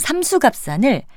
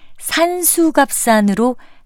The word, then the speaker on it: 삼수갑산: 여기에 나오는 삼수갑산을 산수갑산으로 잘못 알고 쓰는 경우가 상당히 많습니다. 삼수갑산의 발음을 잘못 알아듣고 이런 오류가 나왔을 수도 있고요. 또 삼수갑산을 경치가 좋은 곳으로 잘못 알고 산수갑산이라 했을 수도 있을 것 같습니다.